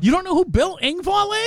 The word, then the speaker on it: Engvall: Man, Brandy says, Bill Engvall. Did I say that right? – Engvall, yeah.